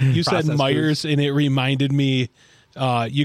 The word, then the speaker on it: Meijer: If you're from the Midwest, you know it's pronounced Meijer's. Like, I was like, it's true, dude. We all call it Meijer's. – Yeah. – Huh. Like Michigan's Michigan Adventures. – No S. There is no S.